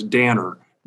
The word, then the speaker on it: Danner: And I've heard my name pronounced, many different ways in many different countries, but the pronunciation of the company is Danner. – Danner, okay. – Like Danner Boots, yeah.